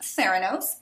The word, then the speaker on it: Theranos: It's pronounced Theranos, but yes.